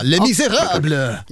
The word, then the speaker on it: Les Misérables: Les Misérables. – Det var fantastiskt uttal. Mycket bra. Les Misérables.